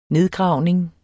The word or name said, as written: nedgravning